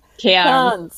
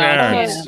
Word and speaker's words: Cairns: Sorry, ich meine, oder wie wird es ausgesprochen? Cairns. Genau. Cairns.